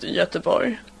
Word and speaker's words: Göteborg: Stockholm, and then Gothenburg, but we pronounce it Göteborg.